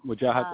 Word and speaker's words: Wajahat: You appear as the father in several productions of The Domestic Crusaders by Wajahat. Is that how you pronounce it? Ali? – Yep, Wajahat Ali.